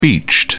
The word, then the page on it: beached: British English